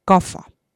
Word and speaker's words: kafa: Serblane ütleb F-iga, kafa.